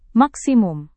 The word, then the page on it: maksimum: Indonesian